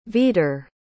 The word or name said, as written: veadar